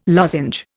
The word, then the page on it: lozenge: Indian